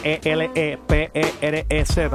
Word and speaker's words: ElePerez: Me pueden conseguir en Facebook por medio de mi nombre, ElePerez, que se deletrea ElePerez, tal como se pronuncia.